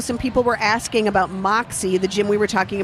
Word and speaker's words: MXE: So that's Moxie. Moxie is how you pronounce it, but it's spelled M-X-E.